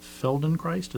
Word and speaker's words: Feldenkrais: I'd never heard of Feldenkrais.